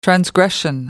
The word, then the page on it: transgression: British English